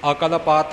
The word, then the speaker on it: अकलपत: अकलपत मुद्रा गुर ज्ञान बीचारिया ले ठीक पाठ कीता भाई साहब जी ने अकलपत जा अकालपत जा होर किसे तरह इसका उच्चारण नहीं करना